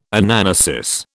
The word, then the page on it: Anamnesis: British English